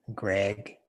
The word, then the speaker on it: Greg: Greg.